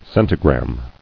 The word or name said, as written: Centigram